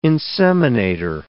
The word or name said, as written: inseminator